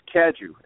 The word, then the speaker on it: Cadieux: Cadieux, because it is French. – That's the right way to say it, but the Americans say Cadieux.